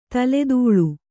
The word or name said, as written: ತಿಲತೈಲ